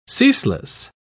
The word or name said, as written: ceaseless